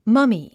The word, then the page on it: mummy: mʌ́mi; マミィ